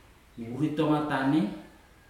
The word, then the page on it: uritoga tane: futuna aniwa